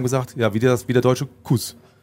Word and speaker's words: Kuss: Wir müssen über Sepp Kuss sprechen. Kuss. Ich glaube Kuss, weil er hat deutsche Vorfahren auch. – Ja, aber selber sagt er möchte Kass ausgesprochen werden.